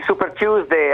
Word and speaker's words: Super Tuesday: Eh sì, Super Tuesday, anche questa volta, almeno Super Tuesday, ma qualcuno...